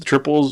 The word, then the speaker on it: Triple: The triple is written as a three, but it's pronounced triple.